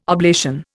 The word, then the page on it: oblation: Indian